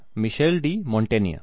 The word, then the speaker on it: Michel de Montaigne: So this is what the pronunciation is, Michel de Montaigne.